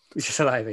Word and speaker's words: Saliba: Saliba, yeah.